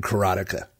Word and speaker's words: Karateka: So just to clarify before we get started, I will be saying Broderbund, and I will be calling this game Karateka, which is the correct pronunciation. I hate to tell the guy that wrote the game, but that's how you say it.